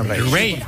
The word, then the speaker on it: Raye: Ray. Ray. Se escribe Raye.